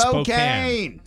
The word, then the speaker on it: Spokane: We learned this one when we got the affiliate out there, Spokane. – Right. – A lot of people say Spokane. I did at the beginning. I was trained. – It's Spokane.